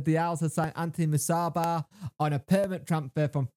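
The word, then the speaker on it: Musaba: Owls land Anthony Masaba. Have I said that right this time? – Yes. – Yes. Brilliant.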